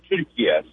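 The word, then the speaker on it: Türkiye: The way Turkish people pronounce it is actually the way it's said Turkiye. So I guess that's why they wanted to change it to the way they speak it in Turkey and the way they pronounce their own country's name.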